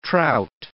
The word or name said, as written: trout